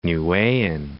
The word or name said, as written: Niuean